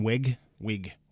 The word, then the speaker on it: Wiig: Kristen Wiig.